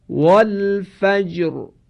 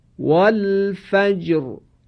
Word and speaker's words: والفجر: لذا اینجا را باید تفخیم بشه، باید درشت و پرحجم تلفظ بشه. تلفظ میکنم، توجه کنید: والفجر والفجر.